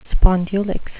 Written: spondulicks

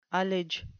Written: ullage

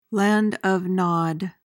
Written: land of Nod

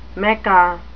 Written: mecca

